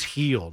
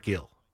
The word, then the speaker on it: Gil: But it's like if you're a Yankee fan, it's at least heel, not Gil.